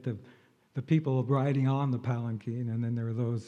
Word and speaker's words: palanquin: By the way, the word is pronounced palanquin, anyway.